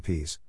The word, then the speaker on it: PISE: It is lengthy, no one knows how to pronounce the acronym PISE, to rhyme with mice, if you're interested, and without the Erasmus part the acronym spells PISE up.